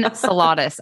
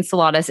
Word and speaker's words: Salatas: Jillian Salatas. I have to say that a couple of times. Jillian Salatas.